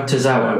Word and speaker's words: Tozawa: Is it Tozawa? – Tozawa. – Oh, right, okay.